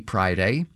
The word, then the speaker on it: Priday: Prior to that, we heard from Rachel Lee Priday, spelled like Friday, but with a P instead of an F.